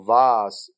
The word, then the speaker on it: vase: OK，下一个 vase 这个词，英式发音读 vase，美式发音读 vase，叫花瓶，花瓶。